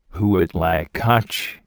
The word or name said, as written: huitlacoche